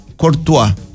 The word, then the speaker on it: Courtois: A Thibaut Courtois.